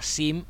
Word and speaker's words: Seam: canción de los Arches of Love os he puesto a Sim, eh, Seam, se escribe Sim, esta canción de su último disco llamado The Pages Global, su canción Get Higher.